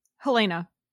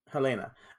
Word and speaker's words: Helena: And I know their names, except for, I think, is it Eastern European Helene? – Helena. – Helena.